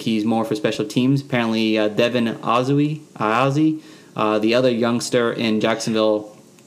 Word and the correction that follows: Ozigbo: How do you say his name? Do you know? – Okay, so Dare Ogunbowale signed with the Jaguars, and then Devin Ozigbo.